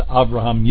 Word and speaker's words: Avraham: I change those back to Avraham, Yitzhak, and Yaakov whenever I remember to, which is most of the time. I like to actually use the Hebraic pronunciation of those names.